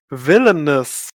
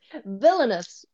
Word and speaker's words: Villainous: Villainous. – Villainous.